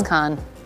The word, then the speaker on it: Caen: He came from Caen. I hope I'm pronouncing that correctly. – I believe Nico? It is Caen. – Caen? – League 2. – C-A-E-N?